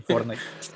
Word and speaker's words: Fortnite: El Frontside, ni nada de eso. – El Frontside. – ¿Qué es eso? – El Fortnite.